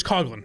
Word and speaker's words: Coughlin: Is there any chance it's Coughlin? – No, it's Coughlin. I've heard people. So, it's spelled Coughlin.